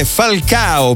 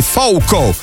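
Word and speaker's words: Falcão: In realtà, ascoltando il testo della canzone, mi sono messo, ho alzato al massimo le mie orecchie bioniche e io ho percepito un Falcao per renderlo più occidentale e Fouco invece come pronuncia portoghese e brasiliana.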